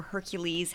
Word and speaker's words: Hercules: Okay, so if we're talking about Greek heroes, the first Greek hero that comes to mind is Heracles or Hercules, however you want to say his name.